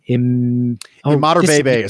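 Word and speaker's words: Imatorbhebhe: Josh Imadorbebe is how you pronounce it. – Imadorbebe, that's right.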